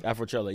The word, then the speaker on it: Afrochella: Yo, you about to do, is it Afrochella? – Afrochella, yes. – I said it right. All right, so Afrochella. Matter of fact, not even Afrochella.